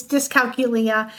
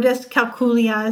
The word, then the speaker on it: dyscalculia: Yes, it's dyscalculia or dyscalculia, as some people pronounce it.